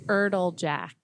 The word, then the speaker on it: Erdeljack: Erdeljack. – Okay. – Just how it's spelled.